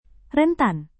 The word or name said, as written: rentan